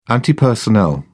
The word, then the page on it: anti personnel: British English